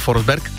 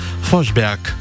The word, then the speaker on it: Forsberg: No, představte si, že takové legendární jméno jako Peter Forsberg, které jsme zvyklí říkat Peter Forsberg, správně by se prý mělo vyslovovat Forsberg.